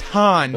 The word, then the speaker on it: Han: Enjoy going Han Solo tonight, dork. – You know, those are two separate characters, and actually it's pronounced Han, not Han.